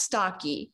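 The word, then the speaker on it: Stache: So my name is pronounced Stocky. – Oh, okay. – So that helps a little bit, but it definitely looks like Stash.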